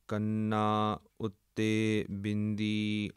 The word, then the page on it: kanna ute bindi: Punjabi